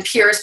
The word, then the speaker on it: Pearce: Heather Pearce Campbell. Um, and Pearce, by the way, is spelled P E A R C E.